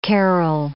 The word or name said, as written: carol